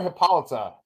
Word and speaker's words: Hippolyta: They call her Hippolyta okay, yeah.